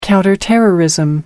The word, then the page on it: counterterrorism: British English